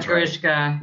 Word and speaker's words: Grishka: And to your point, I think that like one of the characters, I think his name is it Grishka? Is that right? Am I pronouncing that? Yeah, Grishka.